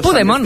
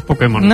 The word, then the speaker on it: Pokémon: El poder que hay en Pokémon. – Pues hazte con todos, Pudemon. – Te diré que es difícil decirlo peor.